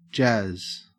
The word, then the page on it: jazz: Australian English